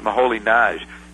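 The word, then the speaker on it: Moholy-Nagy: Yeah, it's Moholy, M-O-H-O-L-Y hyphen N-A-G-Y, but he's Polish or something, so you pronounce it Moholy Nagy.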